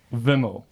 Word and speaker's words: Vimal: There's a slight change in pronunciation. – Okay. – It's Vimal with a short E. – Vimal.